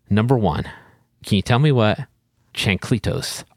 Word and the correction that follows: chancletas: are? Am I saying that right? – Chancletas? – Is that a thing? Yes. Yes. That was much better.